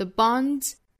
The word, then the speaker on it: bonds: 后面加上的这个 s 应该是读浊音的 z 的发音，再跟前面的 d 再一连起来就应该读成一个 z, 很像我们汉语有滋有味滋的那个声母啊。 The bonds that bind another,